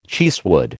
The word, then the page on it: cheesewood: American English